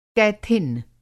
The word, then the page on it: क़ैथिन: قیتھین